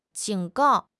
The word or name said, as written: 警告